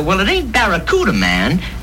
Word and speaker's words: Barracuda: Hey, man, the name of the Plymouth Fastback is the Barracuda. – I can't pronounce Bacaruda. – Yeah, well, look, man, try this. Say back. – Back. – Ra. – Ra. – Coo. – Coo. – Da. – Da. – Now put it all together.